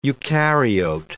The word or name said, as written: eucaryote